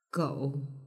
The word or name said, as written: gou